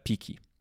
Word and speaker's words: Piccy: jako współzałożycielkę Piki. E, Piki, e, które pisze się mm, trochę inaczej, czyli Mm, tak fonetycznie pizzy.